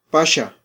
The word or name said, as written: paxa